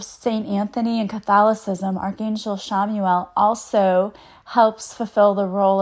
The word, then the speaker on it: Chamuel: All right, and so the last Archangel we're going to talk about today is Archangel Shamuel. It's Chamuel. I say that because it doesn't, you wouldn't think that's how you would pronounce it, but it is.